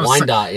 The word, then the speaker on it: Wyandotte: Wyandotte.